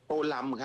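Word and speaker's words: Tô Lâm: โตลำ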